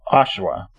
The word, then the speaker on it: Oshawa: Okay, it's Oshawa.